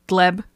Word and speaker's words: Gleb: who I can't decide if his name is Gleb or Gleeb. It's spelled G-L-E-B. I'm going to call him Gleb.